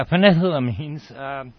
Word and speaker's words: phenethylamines: I'm a little curious about any recent information that may have come out about the phenethylamines. Am I pronouncing that right? – Yeah, phenethylamines.